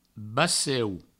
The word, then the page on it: baceu: Provençal